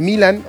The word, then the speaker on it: Milan: Alexis Vega de Toluca, Armando La Hormiga González de Chivas, Guillermo Martínez de Pumas, Santiago Jiménez de Milán, exactamente, de Italia, del Milan, o Milán, como lo quieran pronunciar, Raúl Jiménez del Fulham, de Inglaterra.